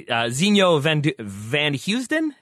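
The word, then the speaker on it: Zinho Vanheusden: I'm going to take us into a much more nuanced direction, because I would like you, if you can, to explain, I don't even know how to say his name fully, Zinho Van Heusden? Is that how you say it? – Yeah.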